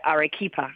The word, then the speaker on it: Arequipa: I read, that at that small racetrack you mentioned, and I know I'm going to butcher the name, Arequipa? – Yeah, Arequipa.